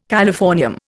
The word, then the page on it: californium: Indian